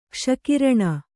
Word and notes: ಕ್ಷಕಿರಣ: kṣa kiraṇa

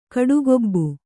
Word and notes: ಕಾಡುಗೂಬೆ: kāḍu gūbe

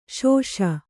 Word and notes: ಶುಷಿ: śuṣi

